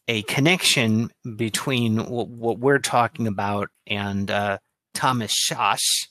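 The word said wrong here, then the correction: Szasz: I've heard that his last name is supposed to be pronounced as you would say Saskatchewan, so it's Sas. – Sas.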